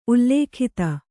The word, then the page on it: ಉಲ್ಲಿಖಿತ: kannada